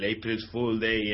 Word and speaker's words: April Fool Day: Eh, como sabréis, pues hoy es el día, el April Fool Day en Norteamérica.